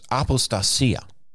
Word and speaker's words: apostasia: I hadn't even looked at the New American Standard to see how they translate it, but the Greek word is apostasia, and you would phonetically spell it A-P-O-S-T-A-S-I-A.